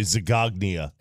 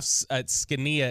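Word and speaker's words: Zgonina: Is really Zagognia. – That's what it looks like. Zagognia. Zagognina is what it looks like. It's Z-G-O-N-I-N-A or something like that. But it's Jeff Scania is how it's pronounced.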